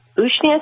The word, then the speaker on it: Usnea: But then we tried that Ushne, I think it's pronounced, USNEA, on my son when he had a really bad abscess tooth.